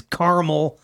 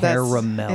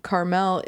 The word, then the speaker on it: caramel: Thank you for finally pronouncing it properly, ladies. Geez, caramel. – That's true. – It's caramel. – It is caramel. Carmel is a city in fucking Egypt, or I don't know.